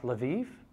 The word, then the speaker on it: Lviv: This is Masha and her husband Doug and their children, and uh, they are missionaries in I think it's pronounced Lviv, L apostrophe V I V.